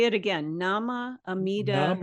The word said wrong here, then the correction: Namu Amida Butsu: Namu Amida, Amida Butsu. That's Butsu. The Japanese form. The Chinese is kind of more difficult to pronounce, but okay. Namu Amida Butsu.